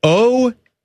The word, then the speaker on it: Oaxaca: Oaxaca. You ever heard of that kind of cheese? – Oaxana? – Oaxana Bayul? No. Yeah. Oaxaca cheeses. House marinated. – I'm gonna go home and Oaxaca later.